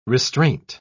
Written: restraint

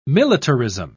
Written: militarism

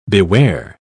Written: beware

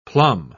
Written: plum

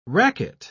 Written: racquet

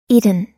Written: eton

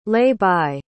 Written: lay-by

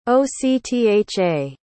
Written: octha

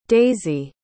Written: daizy

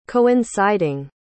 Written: coinciding

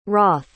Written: Roth

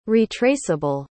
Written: retraceable

